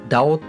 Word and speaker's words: DAO: そうですね、なるほどね、はい、あの町おこしとかで言うとえっと DAO ダオって読むんですけどその辺りを読んちょっと調べてもらうとちょっとわかるかもしれないです。